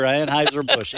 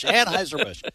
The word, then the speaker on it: Anheuser-Busch: So listen, they Anheuser-Busch, and I corrected my mispronunciation last week. – That's why I brought the story in. Just wanted to hear that again. – Just to hear Anheuser-Busch. Anheuser-Busch. They have taken a beating, and so there is no – it's kind of like Tucker Carlson.